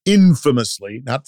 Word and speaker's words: infamously: Roger Ailes is infamously the guy, infamously, accent on the in, because he wasn't a good guy, as we later learned.